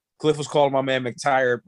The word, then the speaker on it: McTyer: It turned out it wasn't McIre. McIre. McTyer. McTyer. McTyer. – So let's, get, let's get it straight. We, we've been butchering his name on this podcast for three weeks straight. Not MC Big T Y E R McTyer.